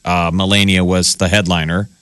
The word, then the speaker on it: Melania: Melania. – Melania.